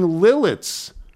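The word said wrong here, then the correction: Lititz: earlier in the show with Will Bond. – And it's Lidditz? – It's Lidditz. Oh.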